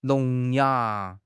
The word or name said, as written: 聋哑